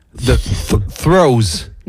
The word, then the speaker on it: Threos: Il saura dire T-H-R-E-O-S. – T-H-R-E-O-S. The throws. – Merci. Yeah. – Merci. Tu peux du coup redire le nom en entier. Donc, Forerunning the Throws.